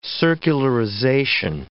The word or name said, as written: circularization